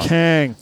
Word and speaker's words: Kang: But right next to that is 571 Bossy with his album called King. I think just that one song. – Kang. – The song is called Like Me. Kang. – You got to say it right. Kang. – Kang. K-A-A-A-A-N-G. – N-G.